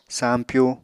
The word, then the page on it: sampyu: apatani